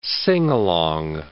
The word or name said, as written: sing-along